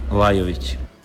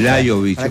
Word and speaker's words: Lajović: Dusan Lajović. – Dusan Lajović. – Exactamente. – Lajović. Aparece como una I, la J como I. Lajović.